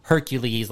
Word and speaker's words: Hercules: You know, like Hercules, like anything that ends in L-E-S is like Lees. – Yeah. – And then you have like... Persephone, which is like P-E-R-S-E-P-H-O-N-E.